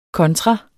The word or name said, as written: kontra